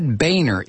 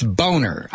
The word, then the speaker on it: Boehner: Obama mentioned Boehner's name several times, and Obama keeps mispronouncing it. It's not Boehner, it's Boehner. I wish somebody would get that across the president. This guy's name is Boehner.